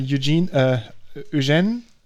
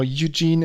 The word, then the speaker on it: Eugène: And I just wanted to mention that and say hello to Eugène. I think it's a proper pronunciation in French. Eugène or Eugene in English or Eugen. – Eugen. – Eugen in German.